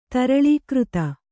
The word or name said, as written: ತರಳೀಕೃತ